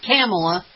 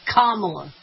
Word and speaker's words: Kamala: Not Kamala, Kamala.